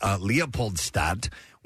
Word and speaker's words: Leopoldstadt: The big winners of the evening were Kimberly Akimbo, which won Best Musical, and Leopoldstadt, which won Best Play. I assume that's how you pronounce that. – Sounds right to me.